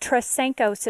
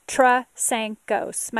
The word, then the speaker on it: Trasancos: Stacy is the easy part, S-T-A-C-Y, no E. But Trisankos is my last name. Trisankos. My kids keep a list of all the misspellings and mispronunciations of our name. But it's Trisankos, T-R-A-S-A-N-C-O-S, just type anything close to it, Trisankos, and you'll be able to find me.